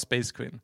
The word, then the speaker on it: Spejzkvin: Eh, S-P-E-J-Z-K-V-I-N. – Ja. Eh, men, det uttalas Space Queen. Det är en, det är en typ av ordvits på vårt gamla bandnamn Space Queen. Mm. Och men om man uttalar det lite dampigt, vad säger Space Kevin, så blir det som Space Kevin mm. och Space Queen.